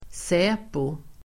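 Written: SÄPO

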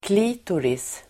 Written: klitoris

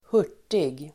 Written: hurtig

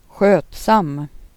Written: skötsamt

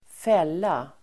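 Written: fälla

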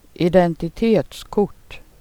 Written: kort

